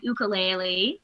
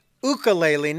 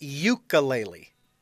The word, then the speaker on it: ukulele: My main instruments that I teach are the guitar, the ukulele, the piano, and I also teach singing and songwriting. – It is pronounced ukulele, not ukulele. – Correct. It is ukulele.